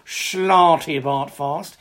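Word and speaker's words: Slartibartfast: Slarty Bartfast.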